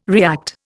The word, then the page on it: react: Indian